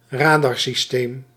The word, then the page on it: radarsysteem: Nederlands; ra·dar·sys·teem